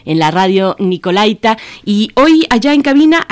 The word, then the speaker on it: Nicolaita: Pues sí, tradicionalmente así le decimos todos, pero, pero nos han avisado desde la misma Radio Nicolaita, que se dice así, así se pronuncia, así se acentúa.